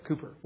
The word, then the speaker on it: Cowper: It's spelled C-O-W-P-E-R. Some would pronounce it Cowper, but it's Cooper, William Cooper.